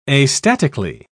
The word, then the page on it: astatically: British English